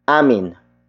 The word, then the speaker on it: amin: It is the direction marker, indicating that the next word is where the act of calling has headed, and that is amin. Amin. Amin. Amin is spelled as A-M-I-N.